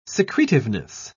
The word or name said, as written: secretiveness